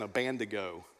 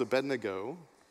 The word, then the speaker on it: Abednego: Growing up in Texas in Sunday school, when we heard the story, it was Shadrach, Meshach, and Abednego. But Beth pronounced it right. It's Abednego.